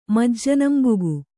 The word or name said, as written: ಮಜ್ಜನಂಬುಗು